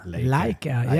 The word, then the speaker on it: Leica: Leica, yeah.